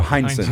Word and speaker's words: Hindson: Ed Hindson is that how you, how you pronounce his last name? Uh, uh Hindson. Hindson. Yeah.